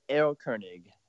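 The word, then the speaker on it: Erlkönig: It would be Errol Koenig. – Errol Koenig.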